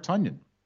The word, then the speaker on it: Tonyan: The X factor here is Robert Tonyan. Rhymes with Funyan.